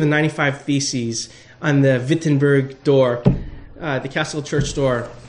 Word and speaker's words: Wittenberg: In German, a W is pronounced with a V. V. Wittenberg.